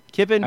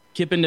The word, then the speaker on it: Kipon: how do you think you pronounce this? K-I-P-O-N. – Yeah, I'd say Kippen. – Kippen, okay.